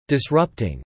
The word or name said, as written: disrupting